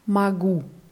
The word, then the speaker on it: могу: Also ich kann heißt ja magu. – Ja magu. – Mhm. Wird mit O geschrieben, aber A ausgesprochen.